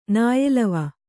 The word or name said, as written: ನಾಯೆಲವ